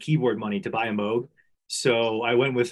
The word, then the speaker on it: Moog: In the keyboard world, do you have a Moog perchance? – I was going, first of all, it is pronounced Moog. I don't mean to be that guy, but it's Wongos.